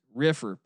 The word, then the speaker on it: Riffr: Once again, do I got to tell you, it's pronounced Riffer, RIFFR. So no E in there.